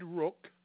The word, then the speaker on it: Rook: Ted Rook.